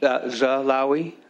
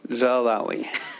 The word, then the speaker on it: Zalawi: Zalawi. – Okay. Thank you. – Don't look at the spelling. Just Zalawi.